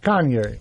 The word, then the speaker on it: Kanye: Kanye.